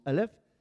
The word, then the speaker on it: Aleph: C'est parce que la première lettre de l'alphabet hébraïque, Aleph, on l'appelle Aleph, elle se prononce Aleph, c'est 1000, mais sa valeur c'est 111.